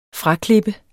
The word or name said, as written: fraklip